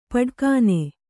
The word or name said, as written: ಪಟಕ್ಕನೆ